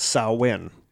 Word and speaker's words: Samhain: And that it is not pronounced how it is spelled. – It is pronounced Samhain. – Yes. – It's spelled Samhain. Movies like the original Halloween get it wrong, or the names of certain Wisconsin independent wrestlers get it wrong.